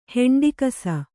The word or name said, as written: ಹೆಂಡಿಕಸ